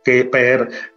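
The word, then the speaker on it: Kheper: Keper, que es realmente es Heper, si lo pronunciase un egipcio antiguo, Heper, Hepri o Heperu. Pero lo llamamos Keper porque la mayoría de la gente lo va a pronunciar claro, así mejor.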